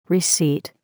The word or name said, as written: receipt